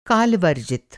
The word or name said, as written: काल-वर्जित